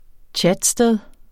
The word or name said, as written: chatsted